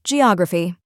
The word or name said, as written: geography